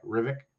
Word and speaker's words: Hrivík: I remember one of his junior teams, his nickname was the Frog, because when you pronounce his last name, it almost sounds like what a frog sounds like, Hrivík.